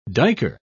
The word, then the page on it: duiker: British English